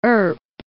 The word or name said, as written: Earp